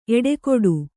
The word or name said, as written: ಎಡಗಡೆ